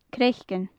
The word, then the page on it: craiceann: gaelic